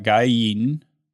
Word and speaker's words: Guy Yeadon: I got Guy Yeadon.